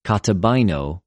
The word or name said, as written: καταβαινω